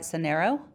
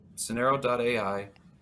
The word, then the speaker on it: Sonero: Sonero? – You did. Sonero.ai.